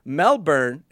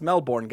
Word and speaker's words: Melbourne: Because I remembered Sydney, Melbourne. It's not pronounced Melbourne, guys. It's Melbourne.